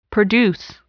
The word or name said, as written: produce